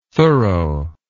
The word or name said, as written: thoro